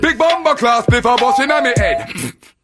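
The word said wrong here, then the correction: bombaclat: It's Bomba Clot. – Bomba Clot. Yeah, C-L-A-T.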